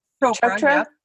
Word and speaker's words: Chopra: Chopra.